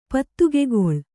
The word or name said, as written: ಪತ್ತುಗೆಗೊಳ್